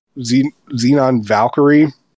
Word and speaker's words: Xenon Valkyrie: I wasn't as much of a fan of Xenon Valkyrie. Or wait, am I pronouncing that right? Yeah, okay.